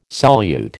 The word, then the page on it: solute: American English